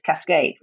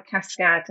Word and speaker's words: Cascãd: But if you say it as it's spelled in French, it's Cascade, which is awful. So that's how the Cascãd, and I put a tilde in for the short A.